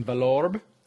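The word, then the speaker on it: Vallorbe: So we both went over and spent 5 or 6 days with them in Valorbe. Is that how you say it? – That's right.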